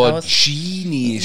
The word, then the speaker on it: Lamborghini: Lamborghini, Da raste ich aus, weil GH wird bei uns G ausgesprochen. Ja, Wie Spaghetti, man sagt auch nicht Spaghetti.